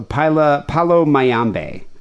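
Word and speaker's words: Palo Mayombe: So Palo Mayambe, I guess is how it would be pronounced.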